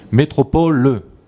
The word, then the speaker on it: Métropole: D'abord, tu sais, tu as déjà mangé le E. Ça ne va pas. Priscilla marquant le E muet. Métropole le.